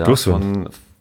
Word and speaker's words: Dursun: Wir haben einen, doch noch einen neuen Stürmer verpflichtet und ähm, sind äh, mit Serda Dur Dursan von ähm, muss mal aufpassen, dass ich es richtig ausspreche. – Ja, Dursun. – Dursun, genau, von, ähm, vom Kräuter Fürth zu uns gekommen.